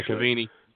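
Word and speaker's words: Cavani: He had a poor performance, and Cavalla, I believe is how you say his name, from Uruguay did not, or Cavini, I think it is. Actually. – Yeah, Cavini.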